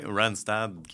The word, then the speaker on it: Randstad: Oui pour, je le prononce bien, Randstad? – Oui, Randstad, qui est une, euh, multinationale euh, d'agence de placement.